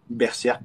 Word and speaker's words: Berserk: On, prononce comment on prononce comment Berserk. Moi, je dis. Ah, quand je, quand je, parle en français, je parle avec l'accent français.